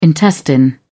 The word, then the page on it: intestine: British English